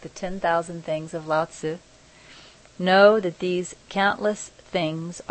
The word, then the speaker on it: Lao Tzu: Anyway, Lao Tzu. I know I'm saying that correctly. Lao Tzu.